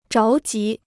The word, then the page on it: 着急: zháo jí